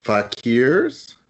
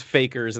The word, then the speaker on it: fakirs: When I was growing up, I heard it was fakers, and then later in life, I learned that was an absolute wrong pronunciation, but I'm grasping at straws here.